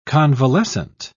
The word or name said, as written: convalescent